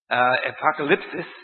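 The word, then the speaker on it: apocalypsis (ἀποκάλυψις): But the Greek word that means the apocalypse, apocalypsis is the way the Greeks pronounce it in any way.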